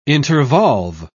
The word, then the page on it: intervolve: British English